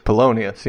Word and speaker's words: Polonius: Polonius, yes.